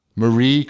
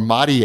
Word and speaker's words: Marie: Some Japanese lady, Marie Kondo. Or Marie, if you want to say it like a Japanese person.